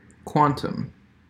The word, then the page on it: quantum: American English